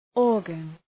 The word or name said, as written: organ